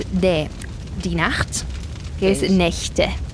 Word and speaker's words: Nächte: Vale, la, lo que pasa aquí es que la A cambia al umlaut, ¿no? Y eso hace que la CH ya no se pronuncie como una J, ¿no? Es die Nacht, como una J, sí. – Pero es die Nächte.